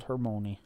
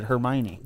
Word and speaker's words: Hermione: Good old Hermione.